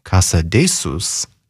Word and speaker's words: Casadesus: I have a classic recording coming up in this hour by pianist Robert Casadesus, or if you prefer his pronunciation by its Catalan roots, as Robert Casadesus, with the Cleveland Orchestra.